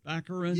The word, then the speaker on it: Baccarin: Daredevil's girlfriend, Morena Baccarin? – Baccarin.